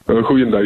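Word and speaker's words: goeiedag: Huidag? Is that how you say hello? Is it Huidag? – Huidag. Yeah, you said it right.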